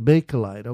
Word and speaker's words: Bakelite: Is it Bakelite or Bakelite? How do you pronounce that? – Yes, All Things Bakelite. – Bakelite. Okay, that's what I thought.